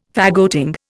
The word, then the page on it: faggoting: Indian